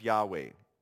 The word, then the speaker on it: Yahweh: Now, in an attempt to pronounce this name, in English, we often write it as Y-A-H-W-E-H, or Yahweh in English.